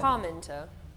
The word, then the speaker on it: Parmenter: Parmenter. – Parmenter. Like you've kind of trodden in something nasty.